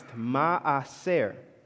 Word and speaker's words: ma'aser: So the word tithe, when you translate it from Hebrew, like the reason it's called tithe, it comes from a Hebrew word called, or pronounced, ma'aser, right? It literally comes from the word aser, which means 10.